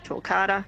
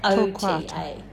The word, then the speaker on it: Torquata: So if I go to Perth and go to a native nursery and ask for dwarf Torquata... – Yep. You will definitely find them. They're very popular. Yeah. Yep. All the nurseries have them. – How do you spell it, Sab? – T-O-R-Q-U-A-T-A. – Torquata.